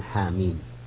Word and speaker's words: حم: هذه السورة افتتحت بهذين الحرفين المقطعين حاميم، ومعلوم أيضا أن حاميم تنطق هكذا، حاء بممدود حركتين وميم مد حرفي أيضا مقدار ست حركات، بينما